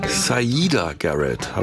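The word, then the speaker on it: Siedah Garrett: Dennis Edwards und Sierra Garrett. Moment, stopp. Zurückgespult. Saida Garrett, habe ich neulich gelernt.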